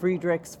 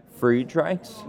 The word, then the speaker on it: Friedreich's: I've heard it pronounced Friedreich's. What is the correct pronunciation of it? – Well, I think, the correct, it's German, right? – Okay. – So I think the correct one is probably Friedreich's.